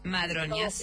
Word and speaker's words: Madroñas: es como madre y doña. – Madroñas. – Todo junto, pero como Instagram no permite la ñ, entonces le ponemos N-I.